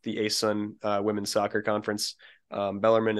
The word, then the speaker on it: Bellarmine: Thursday night, we hosted Bellarmine and tied the game, or Bellarmine, excuse me, common mispronunciation, tied them 0-0 in a game that we flat out dominated for most of the second half and should have won.